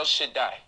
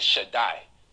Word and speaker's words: Shaddai: When the Daleth and the Yod get together, it pronounces a long I sound. So you get Shaddai.